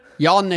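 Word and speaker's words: Jann: Jan jetzt ohne H, dafür mit zwei N. – Das schreibt man so in Davos. Es ist ganz wichtig, dass man meinen Namen mit zwei N schreibt. Bedeutet mir einen Haufen. Und auch betonen, ja. Wenn man zwei N hat, dann...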